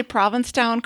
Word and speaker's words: Provincetown: Did I say Provincetown correctly? – Or you got it. – It's not Provincetown? – No, it's not Provincetown. It's the only sort of intuitively pronounced Massachusetts town name, but most people refer to it as P-Town.